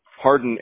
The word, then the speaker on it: Hardin: Harden, H-A-R-D-I-N. – D-I-N, got it. And Andrew, you spell it A-N-D-R-E-W, right? – Yep.